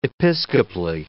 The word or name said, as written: episcopally